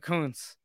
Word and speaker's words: Kountz: I got that right, Kountz.